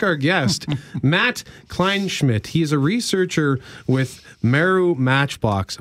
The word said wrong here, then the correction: Maru: Am I saying that correctly, by the way? Is it Meru or Maru? – It's Maru Matchbox, yes.